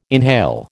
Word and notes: inhale: American English

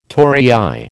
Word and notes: torii: American English

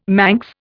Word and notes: Manx: Indian

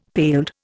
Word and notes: paled: Indian

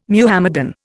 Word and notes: Muhammadan: Indian

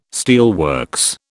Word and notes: steelworks: British English

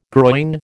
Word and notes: groyne: American English

